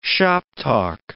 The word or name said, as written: shoptalk